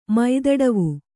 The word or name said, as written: ಮೈದಡವು